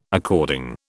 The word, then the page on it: according: British English